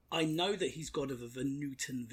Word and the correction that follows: Vanuatu: Village. So mm-hmm. It's not that. – Vanuatu. – Yeah, that one. – Yeah, I can't pronounce it. – Vanuatu. – Vanuatu, I think.